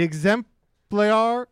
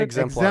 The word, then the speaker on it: exemplar: The exemplar... How do you say that word? – Exemplar. – Exemplar. Mm-hmm.